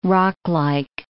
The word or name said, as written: rocklike